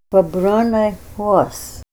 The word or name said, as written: babronay hwos